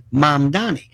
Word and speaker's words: Mamdani: We have this mayor, Zoran Mamdani, okay? And his name is still not pronounced. It's Mom, like Mom and Dad, Donnie, like Donny Osmond, Mamdani.